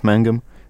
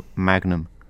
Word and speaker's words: Mangum: um último facto sobre o Jeff Mangum: ele não se preocupa que as pessoas pronunciem o nome dele Magnum, pois é muito parecido as duas palavras.